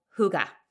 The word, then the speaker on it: hygge: So hygge. It's called hygge. It's spelled H-Y-G-G-E.